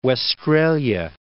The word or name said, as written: Westralia